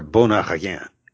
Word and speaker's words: bon appétit: And no pronouncing that final T. Bon appetit.